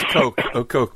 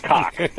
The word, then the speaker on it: Koch: I think their name is Coke, but I understand the reference. – No, it's probably pronounced Koch.